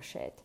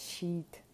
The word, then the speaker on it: shit: І коли ми хочемо таким чином вимовити слово шеть, виходить щіт, листочок. Ну, не не то. Е, Тому така історія. Звук ай з крапочкою, короткий ай, вимовляється без участі Е, рота великої, язик розтікається, і виходить звук щось середнє між е і и.